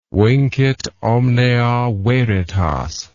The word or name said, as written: vincit omnia veritas